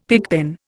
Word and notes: pigpen: Indian

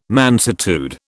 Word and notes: mansuetude: British English

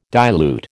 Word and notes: dilute: American English

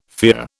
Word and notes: fear: British English

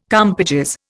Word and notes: compages: Indian

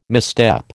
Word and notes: misstep: American English

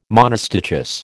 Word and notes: monostichous: American English

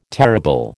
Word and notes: terrible: American English

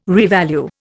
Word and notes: revalue: Indian

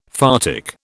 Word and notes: photic: British English

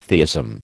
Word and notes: theism: American English

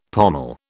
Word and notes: pommel: British English

